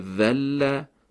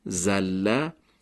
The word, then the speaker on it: ذل: مثالی که در صفحه 28 کتاب زده شده، کلمه ذل به معنای خار شد. اگر همین کلمه با حرف زا تلفظ بشه و گفته بشه زله، معنا عوض میشه و معنای لغزید رو میده.